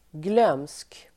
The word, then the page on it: glömsk: [glöm:sk]